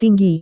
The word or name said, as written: tinggi